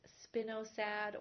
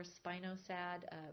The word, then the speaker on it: spinosad: is a spinosad or spinosad. Different people pronounce it different ways. How do you say it, Katrina? – Both ways. – Both ways, yeah.